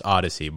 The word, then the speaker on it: Odessi: It's pronounced Odyssey, but it's spelled it's Odyssey.